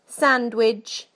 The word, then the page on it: sandwich: British English